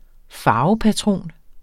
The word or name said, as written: farvepatron